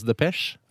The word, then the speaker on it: depesje: Det kalles depesje. – Depesje, eller depesje, vet ikke hvordan man uttaler det. Depesje, kanskje.